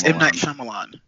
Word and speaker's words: Shyamalan: Oh, I'm going to say his name wrong. That's definitely not it. I was going to get it right before you said anything. Shyamalan. It's M. Night Shyamalan.